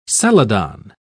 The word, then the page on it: celadon: British English